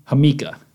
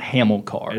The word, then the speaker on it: Hamilcar: Is that the pronunciation? – Hamika. – Okay, so it's not Hamilcar. – It is not Hamilcar.